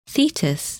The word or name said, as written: Thetis